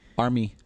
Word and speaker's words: Armie: Is it Armie like the guy from Armie or Arming? – Armie. – A-R-M-I-E. – Armie.